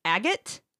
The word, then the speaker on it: Agate: Nick Agate. Am I saying that right? Agate? A-G-A-T-E.